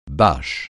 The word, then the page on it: bâche: francais